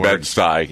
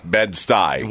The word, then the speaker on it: Bed-Stuy: How do you say that word? – Bed-Stuy. – Yeah. No, it's Bedford. – Bedford-Stuyvesant? – Yep. – Yeah, Bed-Stuy.